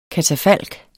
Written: KATAFALK

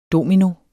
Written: domino

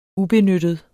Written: benytte

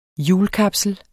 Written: hjulkapsel

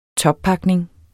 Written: toppakning

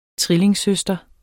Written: trillingsøster

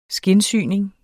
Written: skindsyning

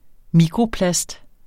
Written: mikroplast

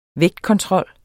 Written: vægtkontrol

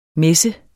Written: messen

